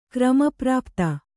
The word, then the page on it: ಕ್ರಮಪ್ರಾಪ್ತ: krama prāpta